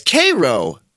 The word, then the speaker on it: Cairo: It's Cairo, interestingly enough. They pronounce it. I had to call their Chamber of Commerce to find that out.